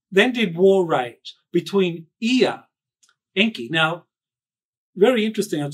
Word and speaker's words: Ea: And the very first custodian was Ea, one of the creator gods, Ea, that's how I will pronounce it.